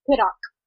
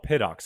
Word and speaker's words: Pittock: I don't even know if I pronounced it right. Is it Piddick or is it Pittock? – Pittock. – Oh, Pittock.